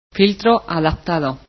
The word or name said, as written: MF